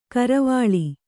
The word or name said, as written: ಕರವಾಲ